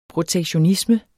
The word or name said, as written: protektionisme